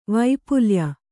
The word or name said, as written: ವೈಪುಲ್ಯ